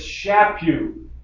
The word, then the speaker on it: Chaput: They want to call him Chaput or something. And he always told me, it's Chaput. It rhymes with slap you.